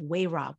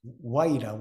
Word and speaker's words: Wayra: And what is the medicine of, and tell me if I'm saying this right, Wayra, W-A-Y-R-A, the medicine of Wayra. What is that exactly? – Wayra.